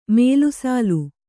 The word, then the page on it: ಮೇಲುಸಾಲು: mēlu sālu